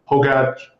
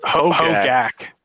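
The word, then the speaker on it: Hogaak: So let's go down to Modern, where everyone's talking about the hot new deck, Hogat? How do you pronounce this? – Hogak. – Holy crap. Hogak.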